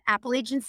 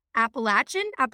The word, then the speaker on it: Appalachian: They say it Appalachian. Like, if you're saying it wrong, we're going to throw an apple at you.